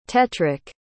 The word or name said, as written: tetric